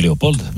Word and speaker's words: Léopold: Est-ce que tu prononces le D du coup ou pas? – On dit Léopold, bien sûr.